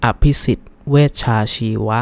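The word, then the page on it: Abhisit Vejjajiva: Thai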